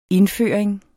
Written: indføring

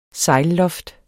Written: sejlloft

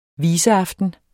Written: viseaften

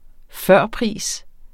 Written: førpris

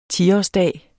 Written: tiårsdag

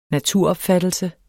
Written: naturopfattelse

